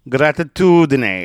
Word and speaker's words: gratitudine: Gratitudine is spelled G-R-A-T-I-T-U-D-I-N-E. Gratitudine. Gratitudine. And grazie is G-R-A-Z-I-E.